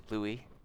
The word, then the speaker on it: Louis: Prince William and Kate Middleton welcomed their third child, Prince Louis, and that is how you pronounce it, Louis. It's the French pronunciation.